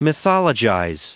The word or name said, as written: mythologize